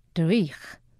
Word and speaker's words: dreich: The Scots have a fabulous word for it, dreich. The ch is pronounced like you are clearing phlegm from your throat, which if you lived in Scotland, you probably would be.